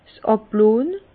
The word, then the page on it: 's Àblonn: Bas-Rhin; Herrlisheim